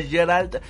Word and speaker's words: Geralt: con Cavill dando vida a Gerard de Rivia. Sin embargo... con Cavill dando vida a Geralt de Rivia. ahí sí está bien pronunciado.